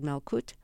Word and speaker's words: Malkut: And the track I want, the composition of yours I want to play is called Malkut. Am I pronouncing that correctly? Yeah, that's good enough.